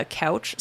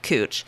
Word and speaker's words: Couch: And then they have, like, in Portland also, there's a Couch Street, but they don't pronounce it couch. They pronounce it cooch.